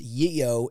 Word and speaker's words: GEO: which, for clarification, apparently is pronounced Yeo in Swedish.